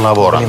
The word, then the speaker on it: lavorano: Io non lavoro, tu non lavori, lui non lavora, lei non lavora, lei non lavora. Noi lavoriamo. – Non lavoriamo. – Да. Noi non lavoriamo, voi non lavoriate. – Lavorate. – Lavorate. І loro non lavoriano. – Lavorano. – Lavorano. – Non lavorano.